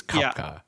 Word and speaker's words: Koepcke: I looked up the pronunciation of her last name because it doesn't sound like the way it's spelled, but it's Koepcke.